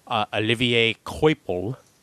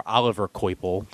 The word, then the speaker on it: Olivier Coipel: pencils and covers by Olivier Coipel, or Oliver Coipel, depending on how you want to pronounce it.